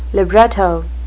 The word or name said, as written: libretto